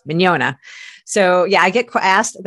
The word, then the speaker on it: Mignogna: I see Vic Magogna. How do you say his name? – Well, okay, he pronounces it Mignogna.